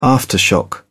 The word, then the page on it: aftershock: British English